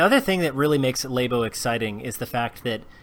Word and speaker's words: Labo: All right, we'll go with Labo. – Like a laboratory. – Oh, that makes me more sense. Okay, we're going to go with Labo.